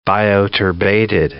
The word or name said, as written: bioturbated